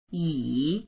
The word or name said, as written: yi3